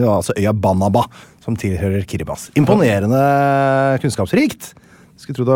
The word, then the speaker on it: Kiribati: Ja, det heter jo ikke Kiribati. – Nei, selv om det mange som leser, vil jo anta det selvfølgelig. – Ja, det heter Kiribati. T-I uttales vanligvis, ikke S.